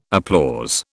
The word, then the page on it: applause: British English